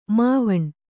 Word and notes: ಮಾವಣ್ಣು: māvaṇṇu

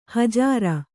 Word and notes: ಹಜಾರ: hajāra

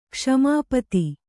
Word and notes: ಕ್ಷ್ಮಾಪತಿ: Kannada

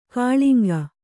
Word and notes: ಕಳಂಕ: kannada

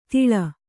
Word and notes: ತಿಳ: tiḷa

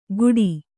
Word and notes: ಗುಡಿ: Kannada

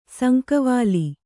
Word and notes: ಸಂಕವಾಲಿ: Kannada

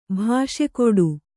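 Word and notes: ಭಾಷೆಕೊಡು: bhāṣe koḍu

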